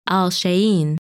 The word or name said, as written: Alshain